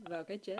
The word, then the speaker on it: Vào cái chết: Is your Vietnamese good? Maybe you can say it. Vào Cà Hai Chết. VAOCAICHET. – Vào cái chết. – I'm not. How do you say it? – Vào cái chết.